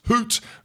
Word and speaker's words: Haute: All you need to do is Google Tom Atkinson at Hoot route. It's spelled H-A-U-T-E. So if you're from Cove, Scotland, maybe sounds different, but it's the Hoot route Alps and you can donate.